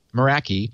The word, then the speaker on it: Meraki: The chips are embedded in, among other devices, certain access points that deliver Wi-Fi to enterprise networks manufactured by Cisco, Meraki, I guess is how you pronounce it, Meraki, yep.